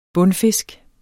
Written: bundfisk

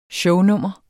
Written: shownummer

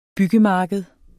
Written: byggemarked

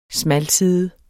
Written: smalside

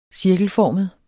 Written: cirkelformet

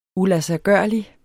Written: uladsiggørlig

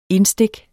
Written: indstik

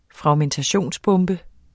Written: fragmentationsbombe

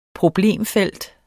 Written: problemfelt

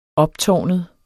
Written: optårnet

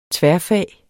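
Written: tværfag

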